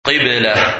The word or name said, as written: Qiblah